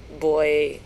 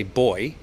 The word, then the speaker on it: buoy: Okay, so in most English-speaking countries, we spell the word B-U-O-Y. We say boy. But I know American people say buoy. So I'm sorry if I've confused people there, and acknowledge that it is pronounced differently in different parts of the world. But B-U-O-Y is pronounced boy in most English-speaking countries.